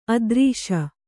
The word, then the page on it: ಆದರ್ಶ: ādarśa